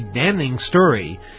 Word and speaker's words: damning: The ACLU said it had obtained documents that told a damning story. I can't believe I pronounced the N on that. Damning story. Not damning. Damning story.